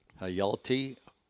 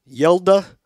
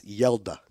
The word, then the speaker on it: Hjalte: So the name is spelled H-J Yelte, which looks like maybe Hjalte, but it's Yelda.